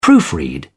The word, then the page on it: proofread: British English